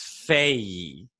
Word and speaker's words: Fage: It's spelled fag. And then they have on the side pronounced Faye.